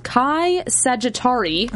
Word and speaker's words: Chi Sagittarii: rock are filled with aluminum and titanium alloy specimens, and that these specimens have passed through an area of the universe called Chi Sagittarii. – Oh, yeah. – Did I say that right? – Yes.